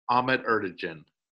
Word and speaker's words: Ahmet Ertegun: And that keyboardist, they threw out names, but is it Ahmet Ertegun? Am I pronouncing that correctly? – Ahmet Ertegun. – Ertegun. There we go.